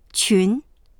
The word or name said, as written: qun2